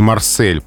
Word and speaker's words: Марсель: Брюссель и Марсель произносятся именно так с твердым «с», а вот «Одесса» с мягким.